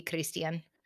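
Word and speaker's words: Cristhian: Would you say the H? – Yeah, they don't do the TH sound. I don't know why they put it in names sometimes, but it would be Christian.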